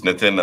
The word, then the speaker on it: Nitin: It's Nitin, and the company is pronounced Emphasis.